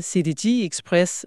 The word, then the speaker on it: CDG Express: Der arbejdes dog på en dedikeret forbindelse, CDG Express, fransk udtale, men den har været længe undervejs.